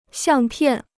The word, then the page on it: 相片: xiàng piàn